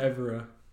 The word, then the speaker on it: Evora: What is it? – It's Evora.